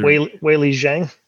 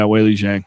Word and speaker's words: Weili Zhang: I don't want to say Weili Zhang. – Yeah. Weili Zhang.